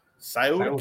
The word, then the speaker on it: Scioto: Scioto County.